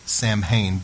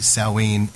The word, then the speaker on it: Samhain: It begins, if you look right at 12 o'clock, you see, we would pronounce that Samhain, but it's really Samhain,